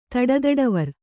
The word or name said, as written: ತಡತಡವರ್